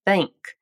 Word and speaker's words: think: Primeiro, muita atenção à pronúncia da palavra think. Esse TH é aquele que nós chamamos de unvoiced, ou não vozeado.